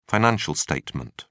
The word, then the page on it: financial statement: British English